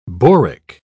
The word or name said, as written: boric